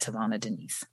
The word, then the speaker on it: Tavona Denise: And that's spelled T-A-V as in Victor, O-N-A, Denise, D-E-N-I-S-E. And then also I'm on Instagram at Tavona Denise.